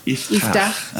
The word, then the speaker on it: Iftah: Well, I'll tell you, it's an Israeli name. It has like a chet. So it's hard for Canadian. So I take it easy on them, but you basically pronounce it Iftah. – Iftah.